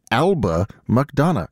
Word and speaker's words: Alba McDonough: That is music of Eba Madonna. I really promised that I was going to try to pronounce that correctly. Alba McDonough. There we go.